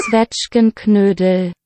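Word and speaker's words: Zwetschgenknödel: Svetchkenknödel. – Svetchkenknödel. – Canoodling. – Everyone made a silent K, but the K is indeed not silent.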